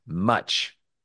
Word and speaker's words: Much: His name is Much. – Okay, great. Cool. – And in this text, it appears M-O-C-H-E. But it's actually in modern times referred to as M-U-C-H. And that's how it's pronounced.